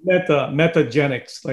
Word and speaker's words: Metagenics: It's Metagenics or Metagenics? How do you spell that? – Metagenics, like Metagenics. N-I-C-S, yeah.